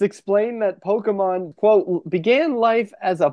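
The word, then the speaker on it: Pokémon: Now, in case you didn't hear how I said that, it is pronounced Pokemon,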